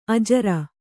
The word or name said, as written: ಅಜುರ